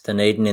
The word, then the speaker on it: Dunedin: Yes, Dunedin in the south there.